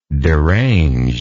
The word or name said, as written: deranged